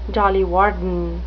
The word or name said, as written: dolly varden